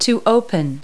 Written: open

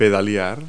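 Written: pedal

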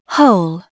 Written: hole

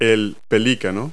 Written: pelican

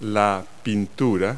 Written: painting

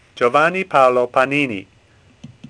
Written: Panini

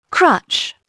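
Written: crutch

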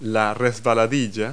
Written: slide

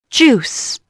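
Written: juice

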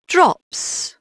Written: drops

